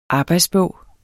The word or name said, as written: arbejdsbog